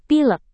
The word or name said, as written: pilek